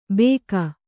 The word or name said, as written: ಭೇಕ